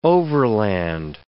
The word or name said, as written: overland